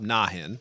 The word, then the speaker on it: Nahin: And he pitches Steve Banerjee and Steve's attorney, Bob Nahin, I think is how you pronounce his name, or Bob Nahin, excuse me, N-A-H-I-N.